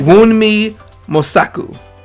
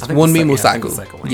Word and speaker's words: Wunmi Mosaku: Uh, I want to make sure I'm saying it right. I think you. Wunmi Mosaku. I think that's what it is. – Wunmi Mosaku.